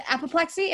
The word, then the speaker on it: apoplexy: I said apoplexy. Am I saying that right, Mary Lou? Apoplexy? – Yes.